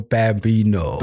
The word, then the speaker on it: Bambino: I said that right, bro. – It's the Bambino.